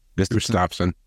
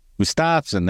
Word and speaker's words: Gustafson: But it also becomes a podcast the following Monday, and I have Steve Gustafson, or Gustav, how do you say it? – Gustafson. – Gustafson, that's right, there's an A in his name.